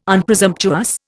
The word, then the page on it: Unpresumptuous: Indian